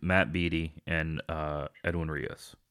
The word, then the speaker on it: Beaty: And like, just to clear this up, it is Beaty. I know, okay. It looks like Beaty if you're looking at it, but yeah, Beaty.